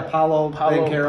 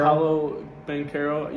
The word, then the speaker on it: Paolo Banchero: I will say, okay, so how do you pronounce his name? Paolo Banchero or something? – Oh, yeah, Paolo Banchero. – Paolo Banchero, yeah.